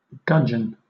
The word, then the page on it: gudgeon: British English